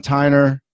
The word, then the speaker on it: Tyner: Today, my guests are Ethan Sakel and Sean Tyner.